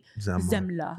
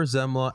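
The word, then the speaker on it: Semmle: That's one that I actually still remember like very vividly, but the only thing that helped me is his name's spelled with an S, but it's pronounced like with a Z. – Zemla. There you go. – Oliver Zemla.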